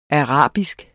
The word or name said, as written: ARABISKE